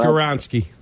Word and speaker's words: Skoronski: Peter Skoronski.